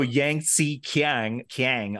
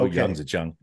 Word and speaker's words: Yangzijiang: But as long as you don't go Yangtze Kiang, I'm happy. Just go Yangzijiang.